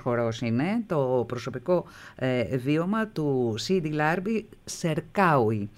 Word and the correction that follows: Σερκαουί: Το είπα σωστά το όνομα, έτσι? – Σερκαουί. – Σερκαουί, εκεί τονίζετε, βέβαια.